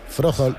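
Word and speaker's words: Frøholdt: Froholt. – Está muy bien pronunciado. – Froholt. – Pero es que la H intercalada.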